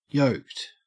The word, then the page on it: yoked: Australian English